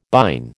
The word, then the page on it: bine: American English